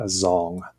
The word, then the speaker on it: Dzong: And so there, the full campus is designed to emulate a Zhong, which is spelled D-Z-O-N-G.